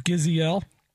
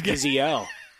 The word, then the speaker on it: Gisele: And I'm pretty sure, guys, it's Gisele. – It's Gisele. That's right.